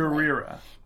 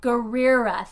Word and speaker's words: Gurira: Gurira. – Gurira.